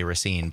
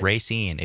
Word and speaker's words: Racine: how native Raciners pronounce it even. – Even other people around the state often say Racine, but right. Racine, if you live there, is how you say it.